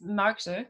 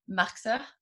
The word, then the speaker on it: Marxer: And then my last name is Marxer, or Marxer, but that's from Liechtenstein, because I'm also Liechtensteinerin.